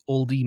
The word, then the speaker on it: olde: We used a pseudo-archaic English that most people would probably read as No Country for Ye Olde Men. But, as you may know, the E on the end of the archaic spelling of old is silent.